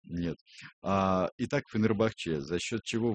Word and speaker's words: Фенербахче: Первая часть, где ударение делать, Фенербахче или Фенербахче? – Фенербахче, говорят.